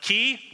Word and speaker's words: chi: It's actually, the Greek word is pronounced chi, which is convenient for us in English because it sort of looks like a keyhole.